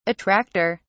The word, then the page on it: attracter: British English